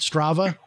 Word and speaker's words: Strava: I got that, the Strava app on my, is that how you say it? Strava? – Goodness. Yeah. – Yeah. I got the Strava app. – Strava.